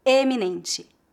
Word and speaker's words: eminente: Note que a pronúncia dessa palavra é feita com a vogal E no início. Eminente.